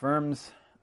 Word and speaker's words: Worms: So this brings us to this momentous event of the Diet of Worms, which I'm told that's the correct German way to pronounce that town name, and it sure helps us, because, of course, if we say it in our English way, it just sounds really funny.